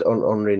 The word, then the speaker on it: Henri: It's spelled Henry, but it's Henri Nouwen.